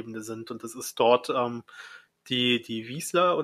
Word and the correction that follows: Wissler: oder wie die heißt? Wissler. Wissler, ich, ich kann die immer nicht aussprechen. – Also hier steht zumindest W-I-S-S-L-I-R.